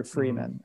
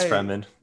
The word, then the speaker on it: Fremen: Freeman or Fremen. I honestly don't know how you pronounce it. – Yeah, but why? – It's Fremen.